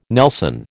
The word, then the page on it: nelson: American English